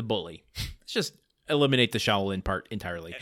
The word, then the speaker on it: Shaolin: Shaolin? Am I saying that right? – Shaolin, I think. – Shaolin. – Yeah, take it from me. I would know.